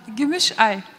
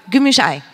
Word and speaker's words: Gümüşay: Gümüşay. – Gümüşay.